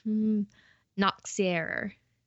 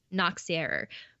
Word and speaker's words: Nachtzehrer: I've written a note that it sounds like Nazi error, but with a k. – Okay. – Nachtzehrer.